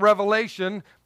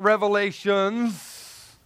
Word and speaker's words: Revelation: By the way, this is John, who wrote the book of Revelation, not Revelations. There's no S, it's just the book of Revelation.